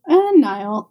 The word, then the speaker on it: Niall: Do you stay with Neil then? – Niall. I know I said it wrong and I'm correcting you.